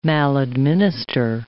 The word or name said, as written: maladminister